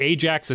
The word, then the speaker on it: Ajax: How do you pronounce the – is it Ajax? – So, Toronto, Ontario. – Okay. – Ajax is like a suburb of Toronto.